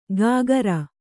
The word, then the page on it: ಗಾಗರ: gāgara